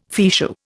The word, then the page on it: fichu: Indian